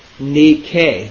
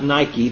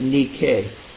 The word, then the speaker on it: Nike: It is spelled N-I-K-E. That's transliteration of the English from the Greek. And it is pronounced Nike, not Nike, but Nike. The word Nikao, N-I-K-A-O,